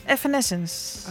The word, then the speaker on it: Evanescence: Evanescence.